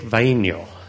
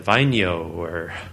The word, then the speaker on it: Vainio: Because it's, and I'm going to mess it up because, yeah, but it's Isaac Vainio. And in Finnish, you pronounce every letter, every vowel. And say, oh, I would have just pronounced that Vainio. And she looks at me.